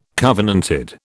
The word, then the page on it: covenanted: British English